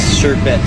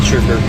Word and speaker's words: sherbet: Because there's no R at the end, like sherbet, not sherbert.